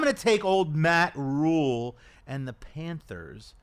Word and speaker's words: Rhule: That he had Matt Rhule say to his face when he covered Matt Rhule wherever he was, that you pronounce it Matt Rhule.